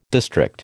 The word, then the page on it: district: American English